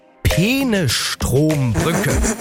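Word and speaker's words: Peenestrombrücke: Es geht um die, und ich versuche das jetzt richtig auszusprechen, es geht um die Penestrombrücke. Ich habe das jetzt bewusst, glaube ich, richtig ausgesprochen. Manuela Schwesig tut sich da ein bisschen schwer.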